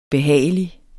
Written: behagelig

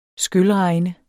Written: skylregn

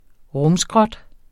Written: rumskrot

